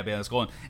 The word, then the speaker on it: Baylan Skoll: And how do you think, like, do you think they're kind of more like this whole situation we have with, is it Baylan Skoll? Is that how I say his name? – Yeah, Baylan Skoll.